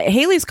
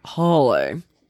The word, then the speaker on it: Halley: uh, Halley's Comet is commonly pronounced rhyming with valley, so Halley, or Daily Haley. One of Edmond Halley's biographers preferred rhyming with Crawley. Mm. Holly.